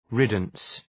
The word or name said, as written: riddance